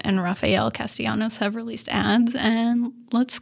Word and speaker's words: Rafael Castellanos: Oh, it's Rafael Castellanos. – Just remember, it's the longest name on the ballot, Rafael Castellanos.